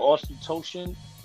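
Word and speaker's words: Auchentoshan: Ochentoshin. Okay, there you go. So that's a 12-year. It's not their entry level. Like most scotches come, you know, 12 years the entry level. But Ochentoshin, this is not their entry level.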